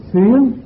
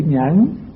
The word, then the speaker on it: nhẫn: Có chỗ đọc là xuyến mà đúng là chữ nhẫn, nhưng mà đọc xuyến thì cái âm nó hợp với đoạn dưới.